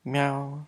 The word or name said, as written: myau